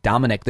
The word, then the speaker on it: Dominick: Dominick. There's no way. – Right, there's no way to mispronounce that. – Some Latino people will say Dominique, but that's okay, because that's how it's spelled.